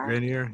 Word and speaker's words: Grenier: Oh, how do you say his last name? Gre- Grenier, Grenier, Grenier, Grenier, yeah.